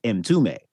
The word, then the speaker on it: Mtume: But I know all of the music because it's from the band M. Toomey. I just never knew how to say the name, like ever. But it's spelled M-T-U-M-E.